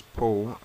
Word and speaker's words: Paul: name is Paul, and my Christian name is Paul, and my surname is Cordell.